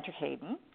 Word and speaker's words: Terheyden: And uh, we have a very interesting guest today, Dr. Nick van Terheyden.